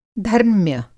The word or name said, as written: धर्म्य